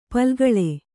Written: ಪಾಲ್ಗೊಳ್

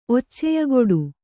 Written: ಒಚ್ಚೆಯಗೊಡು

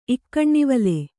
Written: ಇಕ್ಕಣ್ಣಿವಲೆ

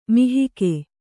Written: ಮಹಿಕೆ

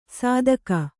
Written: ಸಾದಕ

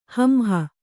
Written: ಹಂಹ

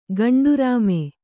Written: ಗಂಡರಾಮಿ

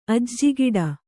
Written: ಅಜ್ಜಿಗಿಡ

